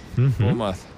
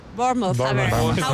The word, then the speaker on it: Bournemouth: Bournemouth. – Bournemouth. – Enseñan a pronunciar Bournemouth.